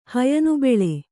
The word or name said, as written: ಹಯನುಬೆಳೆ